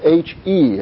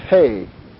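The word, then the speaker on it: hey (ה): It's actually pronounced hey. It's not pronounced he. Here's some Bible teachers go through, this is the he section. No, it's hey. It's a long A sound, or E sound.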